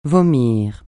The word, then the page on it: vomir: francais